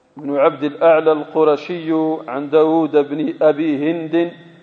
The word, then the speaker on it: داود: داود داود تقرأ داود تكتب داود داود تكتب داود فاعل، لكن تنطق فاعول، ليس في كلام العرب كلمة تنطق فاعل، تكتب فاعل لكن تنطق داود، هذا ما ينطق على خلاف رسمه.